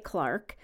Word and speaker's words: Clark: Clark is a clerk, and that's how they say it in the UK, clerk.